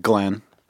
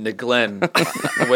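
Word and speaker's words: Nglenn: No, the first one is silent. It's N-G-L-E-N-N. – Okay, yeah, you're right. – Glenn. – Is that where you were thinking? – Nope. – So people they pronounce his name Neglen when they were reading it like at school attendance. – Yeah, the first day of school. – It's Glenn. – Every teacher says Neglen. – Did you guys imagine a lowercase n and a capital G or a capital n and a lowercase g? – Oh, I have both capital to me.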